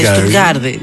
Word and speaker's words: Στουτγκάρδη: Ναι, πέστηκε με προφορά, ρε παιδί μου. – Ναι, είναι Στουτγκάρδη.